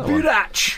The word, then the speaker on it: boorach: It's spelled B-O-O-R-A-C-H, anyway. Anyone wants to get that one. – Burach! There you go. That sounds about right.